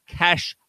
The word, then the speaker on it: cache: It tells you how to say it, and it says cash like money.